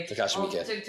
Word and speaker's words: Takashi Miike: Like, again, it was literally, it felt like Takashi Miike. I can't ever pronounce his name. – Takashi Miike. – Takashi Miike.